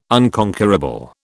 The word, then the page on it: unconquerable: British English